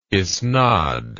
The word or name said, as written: isnad